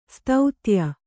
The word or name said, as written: ಸ್ತುತ್ಯೆ